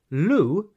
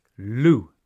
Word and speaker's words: lieu: Lieu s'écrit L-I-E-U. Mais bien sûr qu'en anglais, nous ne le prononçons pas comme en français, lieu. Chez nous, le mot est bien anglicisé, loo.